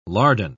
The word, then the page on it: lardon: British English